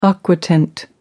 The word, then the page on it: aquatint: American English